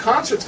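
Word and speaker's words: ConcRT: which as you can guess, we pronounce concert. – Concert, absolutely. – This is concert.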